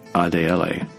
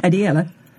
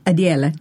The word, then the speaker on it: Adiele: Faith, Adiele. – No. – Damn it. – What is it? – Adiele. – Wait, one more time? – Adiele. – Adiele. – Adiele. – Nice. – Is that the Nigerian pronunciation? – It's as close as I can tell.